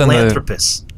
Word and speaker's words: Sahelanthropus: Yeah, that's how they pronounce it. Sahelanthropus.